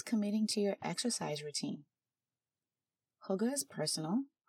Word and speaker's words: hygge: That's spelled H-Y-G-G-E. I don't even know if I'm saying it right, but I know it's closer to hygge. And not Higgy, or Huggy, or Hygge. It's a Danish word.